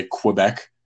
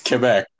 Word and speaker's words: Quebec: And like, that's also why I don't say fucking, like how the amount of people that we know that say Quebec. I'm like, where's the fucking W? It's Quebec.